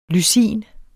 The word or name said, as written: lysin